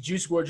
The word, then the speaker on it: Juice WRLD: If that was the case, then it would just be Juice WRLD dropping a posthumous song.